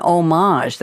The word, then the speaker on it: homage: Galaxy Quest is a parody of an homage, that's the French pronunciation, to sci-fi films and series, especially Star Trek and its fandom.